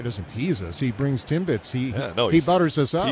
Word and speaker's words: Timbits: I think we got some rally Timbits is what we got.